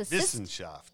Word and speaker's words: Wissenschaft: Right, and we looked up the pronunciation of Wissenschaft, and it's the Wissenschaft. – Yes, and we actually listened to it on Google on how it's pronounced,